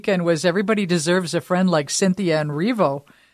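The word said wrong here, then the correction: Erivo: Arrivo. – Arrivo? How do you say it? – Cynthia Arrivo.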